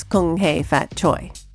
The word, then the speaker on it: Kung Hei Fat Choi: Oh, how do I pronounce that? – Well, now I believe that it is Kung Hei Fat Choi. – Kung Hei Fat Choi.